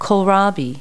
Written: kohlrabi

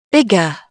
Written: bigger

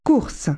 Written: race

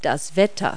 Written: weather

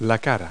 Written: face

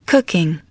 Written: cooking

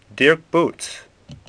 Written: Bouts

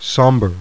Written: somber

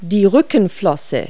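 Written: dorsal fin

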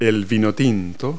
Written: red wine

